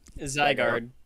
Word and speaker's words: Zygarde: Zygarde.